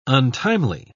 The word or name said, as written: untimely